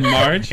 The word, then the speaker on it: Marg: Is it Marge? – It's Marg. – Marg. – Yeah. – Marg Hellenberger.